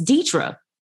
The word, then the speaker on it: Deithra: So that way, when people see my name, they'll think, is her name Deithra, Deathra, Deathray? Oh, it's the E is long, the H is silent. It's Deithra.